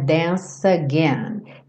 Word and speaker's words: dance: I'm never gonna dance again. Lembra que o E final do dance não é pronunciado. Então a gente junta o C com again e esse C com som de S.